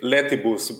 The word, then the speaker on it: Letibus: Well, I say it Letibus, but Letibus. You know, I'm fine with English pronunciation of it, of course.